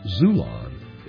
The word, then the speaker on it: Xulon: Get your paperback copy today on Amazon, Barnes & Noble, or from my publisher, Zulon. It's spelled X-U-L-O-N.